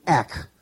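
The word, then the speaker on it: ek (ἐκ): It's pronounced the same way in the Greek, ek.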